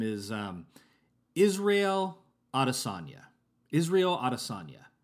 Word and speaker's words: Adesanya: And at a certain point in interviewing and working with Israel Adesanya, Ariel Helwani discovered that the actual way to pronounce Israel Adesanya's name is, in fact, Israel ya. Like he's going to diss on you, you know, like Israel Adesanya. Adesanya.